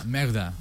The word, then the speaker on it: Merda: Sí, pero con el acento en la E, afortunadamente. – Es francesa, pero... – Ah, Merda.